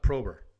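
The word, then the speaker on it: PROBR: It's P-R-O-B-R. It's... Mm-hmm. But it's still pronounced Prober.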